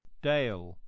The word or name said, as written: dale